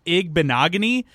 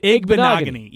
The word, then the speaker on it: Igbinoghene: I settled on Igbinoghunny as far as pronunciation because every broadcaster had a different way of saying it.